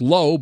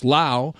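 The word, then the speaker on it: Lowe: Brandon Lau, who looks like it should be pronounced low, but it's pronounced Lau, and he had a couple of home runs, so the Rays win that game.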